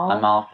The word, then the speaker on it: Amal: And what about Amal, or how do you pronounce her name? Mrs. Clooney? – Amal Clooney.